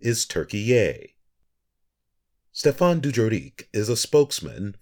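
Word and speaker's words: Türkiye: Turkiye is how the country's name is pronounced in Turkish.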